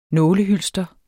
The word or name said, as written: nålehylster